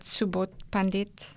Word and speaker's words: Subodh Pandit: And, you know, Dr. Subodh Pandit, I don't know if I pronounced that correct. Is that Subodh Pandit? – I think it sounds right to me.